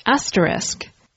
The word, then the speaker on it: asterisk: I had a little bit of hesitation about doing this episode because the pronunciation is so tricky. It's pronounced asterisk. Asterisk. It's common to hear people call it asterisk or asterisk, but the correct pronunciation is asterisk.